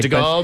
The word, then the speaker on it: Charles de Gaulle: Yeah, they've been putting Chinese signage in the De Gaulle Airport. I'm not sure I'm pronouncing the name right. – De Gaulle, maybe? Charles de Gaulle?